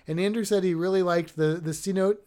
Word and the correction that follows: cenote: I think it's Cenote. I think I just said that wrong. C notes. C notes is actually an email that goes out from the Florida Aquarium, but this is Cenote.